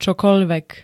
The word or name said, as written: čokoľvek